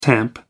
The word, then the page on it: tamp down: American English